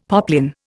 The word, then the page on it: poplin: Indian